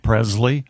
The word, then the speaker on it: Presley: Why are you mispronouncing Presley? – What did I say? – Presley. – It's a hard Z.